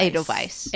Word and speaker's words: Edelweiss: Edelweiss. – It's Edelweiss.